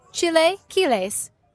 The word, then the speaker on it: chilaquiles: Chilaquiles.